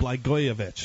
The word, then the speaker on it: Blagojevich: Blagojevich.